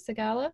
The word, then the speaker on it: Segalla: Is that how you say it? Segala? – That is, yeah.